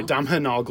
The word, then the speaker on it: Domhnall: It's your boy Donald Gleason's in that one. – I know. – Sorry, Domhnall Gleeson is in that. – Domhnall. Pronounced like domino.